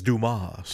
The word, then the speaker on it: Dumas: It's his French show. It is Dumas.